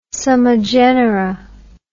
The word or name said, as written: summa genera